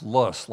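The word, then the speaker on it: loess: Let's start maybe at least 10,000 years ago, when the glaciers melted away and westerly prevailing winds blew this lus soil, pronounced lus, like fuss, onto these beautiful hills.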